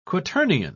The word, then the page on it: quaternion: British English